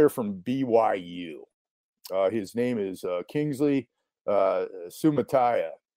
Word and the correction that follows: Suamataia: Uh, he is Suamataia. I'm sorry, I pronounced it wrong uh, Suamataia.